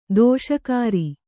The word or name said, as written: ದಿಶಾಕರಿ